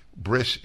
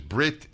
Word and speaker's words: bris: Bris is, there are two ways of pronouncing a lot of Hebrew words. And if one is brit in this case or bris, S or T.